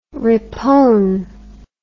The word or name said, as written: repone